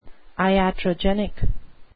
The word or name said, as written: iatrogenic